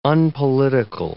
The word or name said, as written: unpolitical